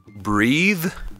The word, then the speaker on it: breath: Mentre cambia totalmente la pronuncia se leviamo quella E, diventa breath.